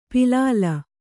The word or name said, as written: ಪಲ್ಲಿಲಿ